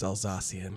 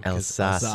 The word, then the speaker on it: Alsatian: And the name Alsatian Wolf Dog was used by the British Kennel Club after World War I. – I'm going to correct you really quick. It's Alsatian. – Alsatian.